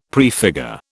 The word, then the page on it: prefigure: British English